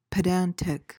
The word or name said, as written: pedantic